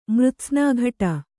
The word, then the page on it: ಮೃತ್ಸ್ನಾಘಟ: Kannada